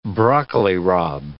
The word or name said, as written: broccoli rabe